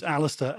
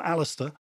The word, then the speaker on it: Allister: Well, the hardest thing in this is you've got to be able to spell my first name, which is Alistair, A-L-L-I-S-T-E-R. Even I spell it wrongly sometimes. So it's, if you can spell Alistair, A-L-L-I-S-T-E-R, and then my surname is Frost, you will find me.